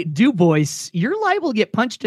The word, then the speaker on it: Dubois: Or oh man, it's a great ride I love doing is going up to Dubois, Wyoming, and it is pronounced Dubois despite the fact that it is named after the French trapper Dubois.